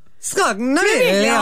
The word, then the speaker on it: Scognamiglio: All right, first up, Beyonce and Blue Ivy, they are both in Francesco Scognamiglio. – You have to say it like this. – Scognamiglio. – Oh, it's so Italian.